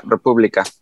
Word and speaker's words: República: Yeah, Republica.